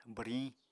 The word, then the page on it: bwriñ: apatani